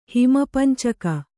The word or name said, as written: ಹಿಮಪಂಚಕ